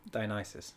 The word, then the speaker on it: Dionysus: Dionysus.